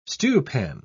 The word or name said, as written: stewpan